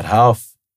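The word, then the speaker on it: Rolls: For anyone who doesn't know what Half is, it's spelled with an R if you want to look it up. – All right. It's spelled with an R and they spell it, right? – Because all in Portuguese, every R is pronounced like an H.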